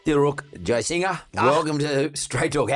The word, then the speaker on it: Jayasinha: It's just there's an invisible G that my ancestors have dropped, but it needs to be pronounced because it's Jayasinha, like someone singing songs.